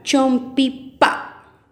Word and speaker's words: chompipa: Y chompipe en poco más se dice chompipa. Repitamos. Chompipa.